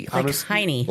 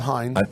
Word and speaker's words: Hynie: He's 20 now, and so that's how – I don't know how you pronounce her last name. It's spelled H-Y-N-I-E. – Heine. – Like Heine. – I'm going to call it Heine.